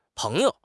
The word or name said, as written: peng2 you5